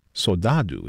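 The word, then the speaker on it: saudade: Soldado is how I believe they pronounce it in Brazil, where composer Chiquinha Gonzaga was born.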